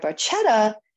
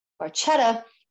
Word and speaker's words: Barchetta: So now the car in the story, in Foster's story, is an MG, but in Red Barchetta, it is a perfectly preserved red Ferrari I66 MM Barchetta. It should actually be pronounced Barchetta, because that's how you pronounce it in Italian.